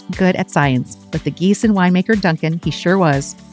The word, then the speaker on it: Giesen: It's Giessen, spelled G-I-E-S-E-N. Sounds like the geese that maybe you see at the park.